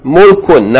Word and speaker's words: mulkun: Line 4, first word, page 24, meem, dhamma, lam with sukoon should be pronounced. Mul, and then kaf with tanween, kun, mulkun, naam, mulkun.